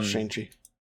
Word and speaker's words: Shang-Chi: Yeah, Shang-Chi.